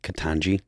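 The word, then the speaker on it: Ketanji: Katanji Brown? Is that how you pronounce her name? – Katanji.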